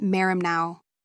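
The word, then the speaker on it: merimnao (μεριμνάω): In the New Testament, anxiety comes from the Greek word merimnao, pronounced merimnao, a verb that means to take apart or divide, carrying the negative connotation of having a divided or distracted mind.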